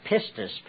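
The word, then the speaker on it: pistis (πίστις): But you see, it came from another Greek word, a word pronounced pistis, P-I-S-T-I-S.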